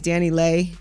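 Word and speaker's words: DaniLeigh: It's Dani Leigh.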